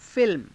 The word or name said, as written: filma